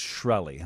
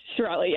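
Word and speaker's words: Shkreli: But the defense, and by the way, you're not pronouncing the K, so I won't either. It's Shirelli, huh? – Shirelli, yes.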